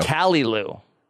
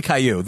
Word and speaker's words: Caillou: Caillou. – No, it's definitely Caillou. The Wikipedia starts with pronounced Caillou.